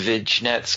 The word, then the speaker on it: vignettes: The vignettes gave me – that's a word I never pronounce right, by the way. – Vignettes. – Gave me vignettes. – There you go. – The vignettes, thank you.